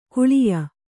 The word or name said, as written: ಕಾಳೇಯ